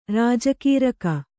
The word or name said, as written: ರೋಜುಗಾರಿಕೆ